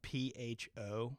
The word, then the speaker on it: pho: It's spelled P H O. – Yeah, pho. Yeah. Okay. Pho. So yeah, Pho. I'm pho. sorry. Yeah, right, pho.